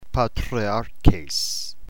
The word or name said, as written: patriarches